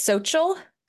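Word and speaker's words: Xochitl: But for now, for sort of the first quarter of the year, for fiction, I'm really, really excited about, okay, I want to say her name correctly. I believe it's Sochil Gonzalez's forthcoming novel, Anita De Monti Laughs Last.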